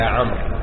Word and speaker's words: عمرو: قال قلت ما لك يا عمرو. الواو في عمرو تكتب ولا تنطق، لا تنطق إلا عند الوصل عمرو بن العاص، ولهذا العائلة المعروفة العمر لا يقال العمر وإنما العمر هكذا عمر ابن العاص.